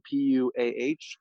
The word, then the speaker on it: PUAH: It's spelled PUAH, called PUA.